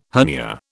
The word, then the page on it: hernia: British English